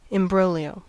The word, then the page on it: imbroglio: American English